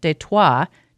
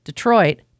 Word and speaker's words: Detroit: Dunnigan says he thinks as more British troops were in the area and eventually controlled it, they changed the pronunciation from the French, Détroit, to the English, Detroit, that we use today.